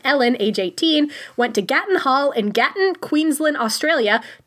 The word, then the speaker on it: Gatton: That is how you pronounce it. Gatton.